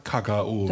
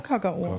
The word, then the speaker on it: Kakao: Ja, ja, sagen ja, der Badener sagt Kakao. – Der sagt Kakao. Mit der Betonung, ganz wichtig.